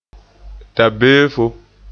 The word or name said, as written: dabeefo